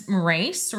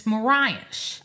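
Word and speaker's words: Morais: It's M-O-R-A-I-S. So I thought it was Moraes or something like that, but it's Moraes.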